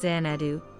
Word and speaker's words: Xanadu: The most remarkable of all, though, was Xanadu, pronounced Xanadu, to the palace of the great Khan.